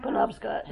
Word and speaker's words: Penobscot: Is that how you say it? – Penobscot.